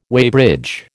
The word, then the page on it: weighbridge: American English